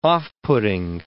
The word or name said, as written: off-putting